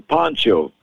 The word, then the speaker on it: Pancho: And so I became Poncho, P-A-N-C-H-O. P-O-N-C-H-O is a ground cloth.